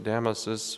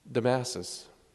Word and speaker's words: Damasus: Today we celebrate Pope Saint Damasus, or I suppose if you put the emphasis on the wrong syllable, it'd be Damasus.